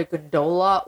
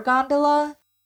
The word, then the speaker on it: gondola: There was like a big debate on how to say it because apparently you can call it a gondola or a gondola.